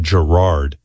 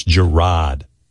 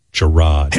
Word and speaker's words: Jerrod: I evidently said Jerrod, but it's Jerrod. Jerrod.